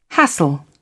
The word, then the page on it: hassle: British English